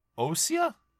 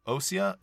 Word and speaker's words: Ousia: We have Ousia, Ousia, and Pneuma. Is that how you pronounce that? I would think. – yes. Okay. I think so.